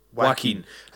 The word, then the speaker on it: Joaquin: If they didn't cast, you know, Yak... How do you say it? – Joaquin.